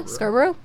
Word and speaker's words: Scarborough: Bernardo is known for initially committing a series of rapes in Scarborough, Ontario, if I'm saying that right. A suburban in Toronto between Scarborough.